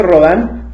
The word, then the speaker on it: Rodin: Se produce Rodán, pero se escribe Rodin.